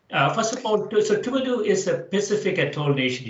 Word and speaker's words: Tuvalu: Is Tuvalu the right pronunciation? – Yes.